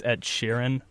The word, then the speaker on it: Ed Sheeran: I've always pronounced it Ed Sheeran, and apparently it's Ed Sheeran. Am I pronouncing it right that time? – Yes.